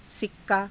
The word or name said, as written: sikkaa